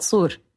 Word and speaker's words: Sur: We call it Sur. It's like it starts with a heavy S.